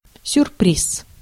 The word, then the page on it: surprise: /səˈpɹaɪz/; /sɚˈpɹaɪz/